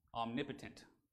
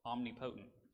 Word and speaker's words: omnipotent: And so in remarking about God, yeah, he's holy, he's eternal, he's omniscient, and now he's omnipotent, or omnipotent, if you want to say it wrong, which I often do.